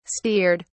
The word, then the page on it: steered: British English